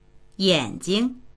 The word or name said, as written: yan3jing